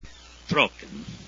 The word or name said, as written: trocken